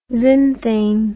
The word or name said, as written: zinthane